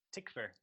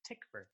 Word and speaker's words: Tykwer: Also, so it's Tom Tykwer is how you pronounce it. And we're going to get letters, that's why I'm telling you, because I had to look it up as well. And apparently the W is pronounced like a V. So it's Tykwer.